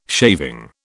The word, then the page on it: shaving: British English